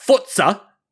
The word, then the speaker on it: Forza: Forza, as Mike makes sure I say it correctly.